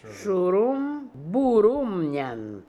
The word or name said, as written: Shooroombooroomnian